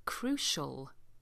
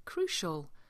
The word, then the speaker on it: crucial: And finally, number 4, crucial. Crucial. This is one of those pronunciations where you have a C with an I and it sounds like a sh.